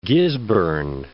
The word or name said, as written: Gisborne